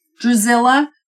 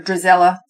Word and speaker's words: Drizella: Yes, like Lady Tremaine, when she's like, don't you think so, Drizella? And she, or Drizella, see, I'm saying it wrong again.